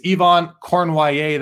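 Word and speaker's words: Cournoyer: You look at his name, it's Yvon Cornoyer. That is how you say it.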